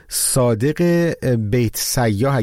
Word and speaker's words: صادق بیت سیاه: این وسط یک مدال هم مثل این که کاروان ایران از دست داد به خاطر حرکتی که ورزشکار ایرانی انجام داد، صادق بیت سیاه، اگر اسمش رو درست تلفظ کنم، درسته مهدی؟ – ملم درسته و از این اتفاقات تو این کاروان زیاد افتاد.